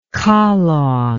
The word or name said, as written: kalong